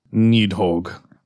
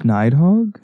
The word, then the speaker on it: Nidhogg: Today's featured creature, Nidhogg. – Oh, see, I always thought it was like Nidhogg. – And on a scale. – I looked up pronunciations.